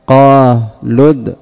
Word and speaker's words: قالوا ادع: فعند درج الكلام وإدخال الكلمة الثانية على الأولى هذه الهمزة تسقط، وهذه الألف لا يتلفظ بها، فيبقى الساكنان الواو والدال، فتحذف الواو لفظا. قالد بعد اللام مباشرة الدال، لد قالد، هكذا تقرأ أو تنطق، قالد، لكن لا تحذف في الخط والكتابة.